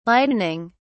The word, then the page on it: lightening: British English